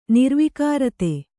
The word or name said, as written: ನಿರ್ವಿಕಾರತೆ